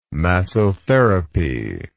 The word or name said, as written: massotherapy